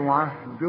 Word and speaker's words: عهدي: عهد الظالمين. الغي الياء دي بتاعت عهدي من النطق اذا وصلت، إنما تثبت إذا وقف. قال لا ينال عهدي عند الوصل عهد الظالمين، لأنها ساكنة واللام ساكنة.